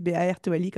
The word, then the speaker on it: Bartoli: donc vous pouvez me retrouver sous le nom Aline Bartoli, Bartoli, comme ça se prononce.